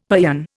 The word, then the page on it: paeon: Indian